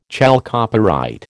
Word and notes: chalcopyrite: American English

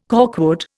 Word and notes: corkwood: Indian

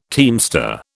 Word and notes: teamster: British English

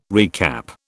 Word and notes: recap: British English

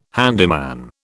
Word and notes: handyman: British English

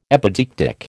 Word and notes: epideictic: American English

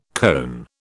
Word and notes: koan: British English

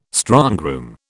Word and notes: strongroom: British English

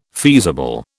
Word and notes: feasible: British English

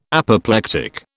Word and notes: Apoplectic: British English

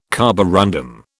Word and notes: carborundum: British English

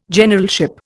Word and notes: Generalship: Indian

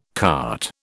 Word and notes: carte: British English

